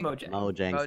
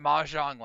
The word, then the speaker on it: Mojang: Anyway, so Mojang, how do you say it, Will? Mojang? – Mojang Studios. – Mojang, whatever. I called it Mahjong last week, and Will got all up in my grill, so I don't want that to happen again.